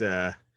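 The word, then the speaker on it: Fehse: I believe he said it's Feese. – Feese. Wow. I was way off. – He said in German, it's Feese. He said a lot of Americans say Feese.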